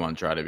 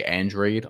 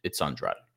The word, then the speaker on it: Andrade: These are all the things that Demetrius Andrade, and I'm calling him Andrade because that's really how you pronounce that name. People can say Andrade on the broadcast, but it's Andrade.